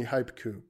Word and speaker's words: Hipku: It's called Hipku or Hypeku. Yeah, probably Hypeku because it's actually turning any IP address, including IPv6, into a haiku. So it's definitely Hypeku because it rhymes with haiku.